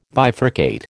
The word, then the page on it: bifurcate: American English